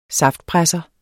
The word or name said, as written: saftpresser